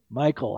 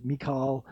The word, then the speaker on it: Michal: No, Michael. I don't know how you pronounce her name. Looks like Michael, but it was Michal.